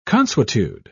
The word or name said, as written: consuetude